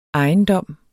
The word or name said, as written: ejendom